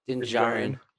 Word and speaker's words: Din Djarin: Mando, Din Djarin. Is that how you say his name? – Din Djarin.